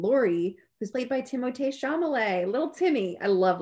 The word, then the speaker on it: Timothée: I love saying Timothée when I realize that's how you pronounce his name. People are like Timothy.